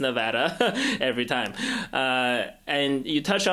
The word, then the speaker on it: Nevada: Sometimes you hear Nevada, you know that this is new to them. It's Nevada, and that's important for everybody who comes to organize here.